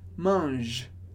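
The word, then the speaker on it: mangent: E mange, como a gente vê na aula de conjugação, esse ENT do final da conjugação de eles e elas não é pronunciado. Por isso a gente fala mange, mange.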